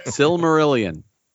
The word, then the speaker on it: Silmarillion: I can't even say Similarians. – I have no idea if I'm saying it right. – Silmarillion.